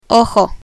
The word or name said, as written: ojo